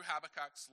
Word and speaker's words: Habakkuk: So this morning, if you have your Bibles, you can turn to, I'm going to try and say this guy's name right, Habakkuk? It's the closest thing I listened to it on the Bible Gateway to get him to pronounce it a few times, and that's the way it comes out when I speak it.